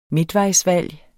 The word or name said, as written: midtvejsvalg